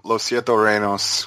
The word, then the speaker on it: Los Siete Reinos: But so there was that report from LSR from Los Siete Reinos, which is the Spanish kind of band site. – Nice pronunciation. Good job.